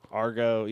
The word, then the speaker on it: Argo: But what'd you say his name was? Ugo. – Victor Argo. – Argo, yeah, Victor Argo.